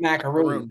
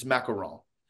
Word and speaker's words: macaron: I thought it was macaroon. Not that I can really. – No, if you pronounce it in French, it's macaron.